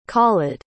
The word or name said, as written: khalid